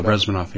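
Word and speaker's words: Bezmenov: And the key thing that Yuri Brezhmanov, I guess, how do you pronounce his last name? Brezhmanov? – Yeah, Brezhmanov, yeah.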